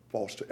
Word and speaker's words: Foster: Bishop Major Foster, Amen, 13724 Old State Road, Allendale, Delaware. – And your last name is spelled? – Foster, F-O-S-T-E-R.